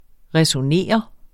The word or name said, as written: resonere